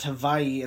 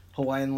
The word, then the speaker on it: Hawaii: But it's actually pronounced Hawaii, and the reason for that is because V in the Hawaiian language, or W, I should say, sounds like a V.